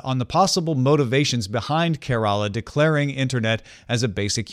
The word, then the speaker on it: Kerala: He says it's Kerala, the same as care, as in caring for someone.